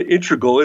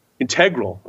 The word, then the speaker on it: integral: It's not integral. It's integral.